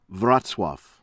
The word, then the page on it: Wroclaw: American English